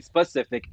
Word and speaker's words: specific: Yeah, we never Yeah, we never pronounced the S. And she used to say, if you're not going to say it right, I'm not talking to you about it. This isn't an ocean. This is not how it works. So, like, I learned real quick the difference between specific and pacific.